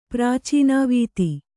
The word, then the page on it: ಪ್ರಾಚೀನಾವೀತಿ: Kannada